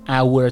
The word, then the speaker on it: our: hour تلفظ میکنید پس O-U-R رو هم باید شما hour تلفظ کنید چون که h که اول ساعت هست سایلنته یعنی بی صدا هست تلفظ نمیشه